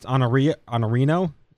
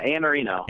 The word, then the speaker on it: Iannarino: And apparently, it's Iannarino. – Yeah, Iannarino. – Iannarino. Yep. All right. – The eye is silent. It's kind of weird.